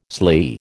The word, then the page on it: sley: American English